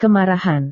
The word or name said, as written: kemarahan